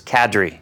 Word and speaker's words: Kadri: While we're talking about Francouz, I think we should say it is Nazem Kadri. – I don't remember if we talked about this in our last podcast, but I keep hearing Kadri. Mm-hmm. It's Kadri.